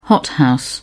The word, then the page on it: hothouse: British English